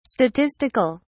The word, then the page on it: statistic(al): British English